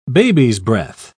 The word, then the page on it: babies'-breath: British English